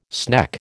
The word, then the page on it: sneck: American English